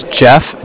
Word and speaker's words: Jeff: Okay, my name's Jeff, J-E-F-F, and I'm with the Bank of Cardiff. Cardiff is spelled C-A-R as in car, D-I-F-F?